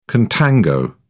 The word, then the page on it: contango: British English